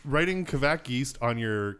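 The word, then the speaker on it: kveik: I say it, like closer to quack than Kvike, like Kvike.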